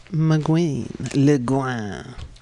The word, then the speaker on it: Le Guin: So if you're into the book club idea, check out this really interesting sounding novel, The Dispossessed, Ursula Le Guin. That's L-E-G-U-I-N. I was just putting a little French twist on it. Because it's a French name, Le Guin. Or Le Guin, if you must pronounce the N.